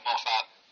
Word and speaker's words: Moffat: How do you say Moffat? – Moffat. – Okay. Moffat. Okay. He's Canadian. – I'm not Canadian. I'm fucking Australian. Oh, Moffat is. – Moffat is Canadian.